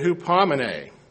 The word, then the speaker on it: hupomene: It is pronounced, transliterated, hupomene, Strong's 5281.